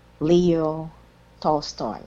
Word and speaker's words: Leo Tolstoy: Leo Tolstoy.